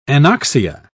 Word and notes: anoxia: British English

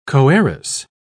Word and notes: coheiress: British English

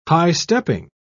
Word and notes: high-stepping: British English

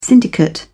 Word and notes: syndicate: British English

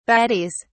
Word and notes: baddies: British English

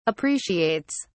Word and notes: appreciates: British English

